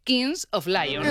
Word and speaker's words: Kings of Leon: Bueno, y luego en el Mad Cool, claro, justo actuaba en el momento del partido España-Bélgica el grupo estadounidense Kings of Leon.